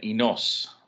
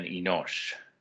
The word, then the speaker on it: Enosh: Your Bible might have Enos rather than Enosh. If that's the case, it's probably influenced by the Septuagint and you're getting the Greek transliteration. But the Hebrew will give you the sh sound at the end rather than the hard s sound.